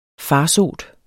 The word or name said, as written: FARSOTERS